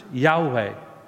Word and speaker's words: Yahweh: And there is the personal name for God in the Old Testament, the Hebrew name written with just four consonants, YHWH, that we pronounce Yahweh.